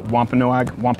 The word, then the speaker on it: Wampanoag: Let's look back to this account that we have in history in 1621, where the early pilgrims with a Wampanoag, I always get the name wrong. – Yeah, that's. I think that's the best pronunciation.